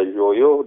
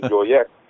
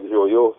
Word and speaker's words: Joyeux: And I figured, well, since they could not pronounce my last name either, Joyo, they kept telling me Joyex. So I said, just say Thoreau, because it sounds like Joyo, so that made it easier.